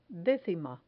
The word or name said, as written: decima